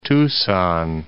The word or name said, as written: Tucson